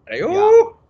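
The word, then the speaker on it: Aiyuk: How do you say his name? Ayuk? Is that right? Brandon Ayuk. – Ayuk.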